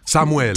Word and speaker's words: Samuel: Samuel. – Damn, I sound so white. Samuel. Do I have to say it like that? – Of course, it's in Spanish. – Samuel.